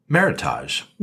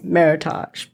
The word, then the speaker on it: Meritage: So, the term is a blend of the words merit and heritage and pronounced the same, meritage. – But yep. – That's not right because you meritage. Don't go around saying heritage.